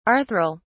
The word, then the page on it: arthral: British English